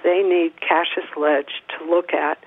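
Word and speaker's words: Cashes Ledge: For the folks who are wondering what we're talking about and want to go look it up, it's spelled like cash, like money, E-S, Cache's Ledge, and the Conservation Law Foundation and other groups are working to protect the place.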